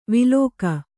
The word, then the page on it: ವಿಲೋಕ: Kannada